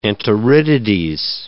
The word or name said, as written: enteritides